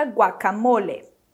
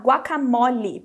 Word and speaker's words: guacamole: Então, para dizer guacamole, o mo a gente fala como na palavra moço e o le assim como na palavra ler. Então, mais uma vez, Guacamole. Guacamole.